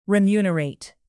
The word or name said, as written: remunerate